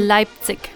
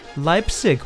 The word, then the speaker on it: Leipzig: From Leipzig, did I pronounce that right? – Leipzig. – Leipzig.